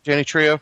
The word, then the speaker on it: Trejo: Danny Trejo.